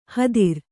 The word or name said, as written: ಹತ್ತಿರ